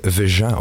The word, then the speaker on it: Veja: Nee, zij, zeggen Vega op zijn Frans. Ah, dus het zit in de Romaanse talen, dus het is helemaal niet Spaans. Het is Portugees, het is een Frans merk ook, eh, wat de mishmash, wat...